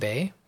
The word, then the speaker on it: Bae: You can find me on Twitter at Kevin Bay.